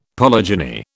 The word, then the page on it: polygyny: British English